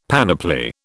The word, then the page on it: panoply: British English